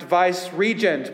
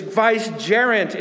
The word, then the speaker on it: vicegerent: Sometimes you hear it pronounced vice-regent, but it's vicegerent.